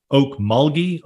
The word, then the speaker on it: Okmulgee: And I see here that, and I want to make sure I get the name right, is it Okmulgee, Oklahoma? – Yes, sir.